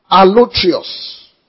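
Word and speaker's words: Allotrios (ἀλλότριος): Allotrios. A-L-L-O-T-R-I-O-S. It's only used once and it's here. A-L-L-O-T-R-I-O-S.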